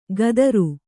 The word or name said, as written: ಕದರು